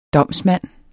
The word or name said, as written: DOMSMANDEN